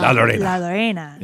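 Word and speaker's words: La Llorona: What was, how did you say it again? It's the Curse of La Lorena. – Yeah. – La Lorena.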